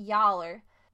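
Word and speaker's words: y'all're: Entonces, no recomiendo que uses esas frases muchas veces, especialmente yaller. Yaller es una combinación de tres palabras y se escribe como Y apóstrofo A L L apóstrofo R E.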